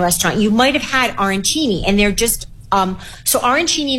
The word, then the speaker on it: arancini: And if you are looking for something that is a little more pasta-based, tell me about the arancini casserole. Did I say it right? – You did.